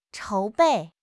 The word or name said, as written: 筹备